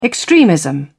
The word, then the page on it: extremism: British English